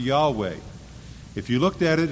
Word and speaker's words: Yahweh: and so it's pronounced Yahweh. Yahweh.